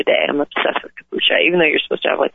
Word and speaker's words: kombucha: My pug, And I may not pronounce this right, kombucha? – No, kombucha, lavender kombucha, yeah.